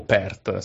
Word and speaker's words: Perth: Sono a Perth, o Perth se preferisci la pronuncia.